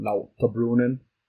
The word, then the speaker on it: Lauterbrunnen: I think the whole, if you want to say it like the Swiss person, Lauterbrunnen, it's like Wengen, Steckelberg, this whole area is just, it's gorgeous.